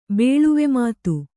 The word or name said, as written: ಬೇಳ್ವೆಮಾತು